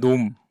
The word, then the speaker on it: 놈: 저는 영어 NORM을 한글로 발음한 놈. – 예, 놈. 특히 네. R 발음을 강조해서 발음한 놈이라는 네.